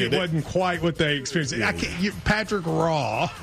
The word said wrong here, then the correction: Roy: Yeah, R-O-Y, but it's pronounced Wah.